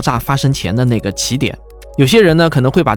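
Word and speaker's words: 奇点: 所以，这里的“ 奇” 的词义呢，是特殊的，而不是非双数的奇偶的那个“ 奇”。所以，大多数科普同行都会把这个词念成起点。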